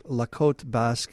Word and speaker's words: La Côte Basque: You mentioned the short story, the La Côte Basque, 1965. – Yes. – How did I do on my pronunciation? – Very good. I think that's exactly the way I pronounce it.